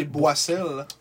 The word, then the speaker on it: Boissel: ramassé sur la palette de euh, c'est Boissel. Boissel, ouais. Avec deux S. Ouais, il y a deux S, t'as raison. Faut pas prononcer ça comme un Z dans ce temps-là.